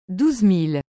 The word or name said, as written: 12000